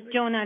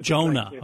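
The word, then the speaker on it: Joona: Oh, yes, Juno Linna. – Jonah, okay. – Jonah in the Bible, but this is a Finnish spelling of his name.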